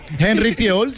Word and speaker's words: Henry Fiol: Henry Fiol. ¿Se dice así? – Henry Fiol, sí.